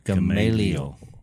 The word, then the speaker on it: Gamaliel: And how do you say that guy's name again? Because I think he was a lawyer. – Gamaliel.